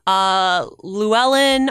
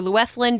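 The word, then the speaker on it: Fluellen: Llewellyn or Llewethlyn, depending on what your Welsh pronunciation is going to be, is really great.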